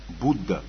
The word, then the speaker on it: Будда: И первым идет Будда. Будда. Будда на санскрите пишется как Буддха. Ха, помните звук, я вам говорил? И он не читается, поэтому появляется Будда.